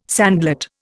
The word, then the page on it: sandlot: Indian